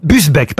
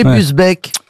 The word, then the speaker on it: Busbecq: Augier Gillin de Busbecq, pardon, j'ai écorché son nom. – Il semble bien aussi. – Ça, c'est pour ça, oui. – Est-ce que vous pouvez appeler Busbecq? – B-U-S-B-E-C-Q. Olivier, pardon, Augier Gislin de Busbecq.